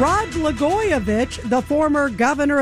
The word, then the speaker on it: Blagojevich: And we have former governor Rod Blagojevich.